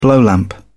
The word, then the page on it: blowlamp: British English